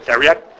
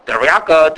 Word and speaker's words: theriac: I pronounce it theriac. That's how we would say it. Of course, we've got Chiako or Dariaka, different ways of saying it.